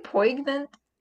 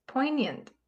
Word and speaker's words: poignant: How do you say that word? – Poignant. – Poignant. Yeah, that just doesn't sound right. – I feel like I need to pronounce a G in there somewhere.